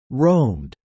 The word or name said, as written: roamed